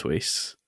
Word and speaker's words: Whis: Have we also confirmed that it's Whis, the pronunciation? – Yeah, I'm pretty sure it's Whis.